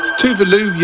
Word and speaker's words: Tuvalu: Tuvalu, yes, that is the right pronunciation, honestly.